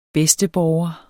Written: bedsteborger

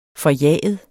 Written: forjaget

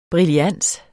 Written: brillans

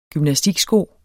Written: GYMNASTIKSKO

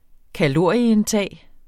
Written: kalorieindtag

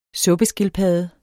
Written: suppeskildpadde

